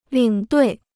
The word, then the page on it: 领队: lǐng duì